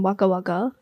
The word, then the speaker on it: Wagga Wagga: But it was filmed in Wagga Wagga. I'm not sure if I pronounced that right. – You did pronounce that pretty well.